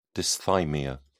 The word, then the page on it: dysthymia: British English